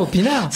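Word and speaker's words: Aupinard: Alors ça s'écrit pas O, ça s'écrit AU et rien à voir avec la boisson. Hein, rien à voir, effectivement, c'est la blague. – Mais ça se prononce comme ça, Opinard. – Ça se prononce comme ça, oui, tout à fait.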